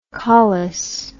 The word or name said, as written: caulis